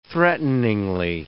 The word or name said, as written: threateningly